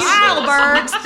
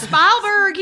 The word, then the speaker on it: Spielberg: Yes, Spielberg. That's Spielberg.